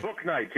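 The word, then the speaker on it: Bouknight: Boatknight, James Boatknight.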